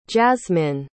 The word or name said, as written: jazmin